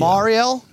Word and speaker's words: Mariel: Am I saying it wrong? – It's a hard Mar. – Yeah. Marielle? – There you go. – How do you spell that? – Mariel. – Oh, all right. So, okay. So I was thinking Ariel with an M, right? Like the but it's Mariel.